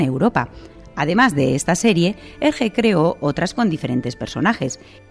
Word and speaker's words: Hergé: Fue un historietista belga, más conocido por el seudónimo artístico de Hergé, por ser esa la pronunciación de sus iniciales invertidas, R.G., en francés.